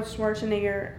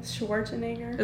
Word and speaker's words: Schwarzenegger: and also Arnold Schwarzenegger. Is that how you say his name? Schwarzenegger? – It's how Americans say his name.